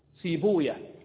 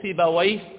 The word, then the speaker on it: سيبويه: فهذه الألفاظ الأعجمية نطقها أهل الحديث كما ينطقها أهلها، فلذلك أهل الحديث يقولون راهوية سيبوية حمدوية خالوية وهكذا سعدوية، عرفت؟ وأهل الأدب واللغة ينطقونها كما تغير العرب الألفاظ الأعجمية على قواعد العربية، فيقولون سيبويه وراهويه وحمدويه وخالويه وهكذا ونفطويه.